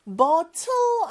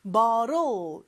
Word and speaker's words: bottle: 근데 T 발음이 좀 이상한데요. bottle 아닌가요? 물론 bottle이라고 해도 되지만 특히 북미권에서는 요 T가 사이에 그 모음소리 사이에 껴있을 때는 water가 water가 되는 것처럼 real과 비슷한 발음이 아주 살짝 돼요. 그래서 bottle 이렇게 표현을 하는데 혀가 입천장에 살짝 닿았다가 떨어지는 느낌입니다.